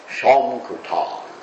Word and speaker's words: Sankirtan: And Srila Prabhupada said that this preaching of Krishna consciousness, which he called Sankirtan, which Lord Chaitanya called Sankirtan, Sankirtan, that's how Prabhupada pronounced it. Actually pronounced it Shomkirtan. Because in Bengali you pronounce the S's like that with an SH.